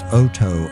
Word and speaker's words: Oto: I think it's pronounced Oto, O-T-O.